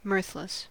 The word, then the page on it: mirthless: American English